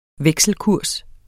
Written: vekselkurs

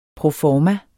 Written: pro

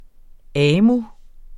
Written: AMU

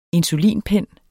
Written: insulinpen

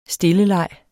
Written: stilleleg

